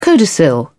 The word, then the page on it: codicil: British English